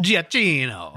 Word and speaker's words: Giacchino: Giacchino.